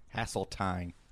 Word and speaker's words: Haseltine: Haseltine.